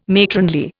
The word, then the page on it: matronly: Indian